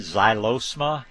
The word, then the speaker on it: Xylosma: look at Xylosma. It's spelled X-Y-L-O-S-M-A, like xylophone, X-Y-L.